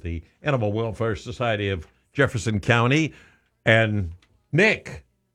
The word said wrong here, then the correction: Nix: Nix. You got to get the name right. – Nick, N-Y-K. – N-Y-X, Nix.